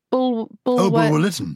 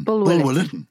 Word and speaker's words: Bulwer-Lytton: Edward Bulwer-Lytton. – Bulwer-Lytton.